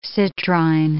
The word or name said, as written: citrine